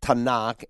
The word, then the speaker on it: Tanakh: And then Tanakh, N, you hear the N sound, that's for Nevi'im. That's the Hebrew word for prophets. And then there's a hard C sound. It comes out, Tanakh is spelled, by the way, T-A-N-A-K-H. And the K sound is for, you've heard the word chesed. It's actually like a K-H sound.